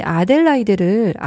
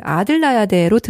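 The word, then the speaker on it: Adelaide: 아, 참, 이 아델라이데를 아들라야데로 들으시는 분들 간혹 계시더라고요. – 예. – 자, 발음 잘 하셔야 돼요.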